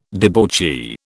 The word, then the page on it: debauchee: British English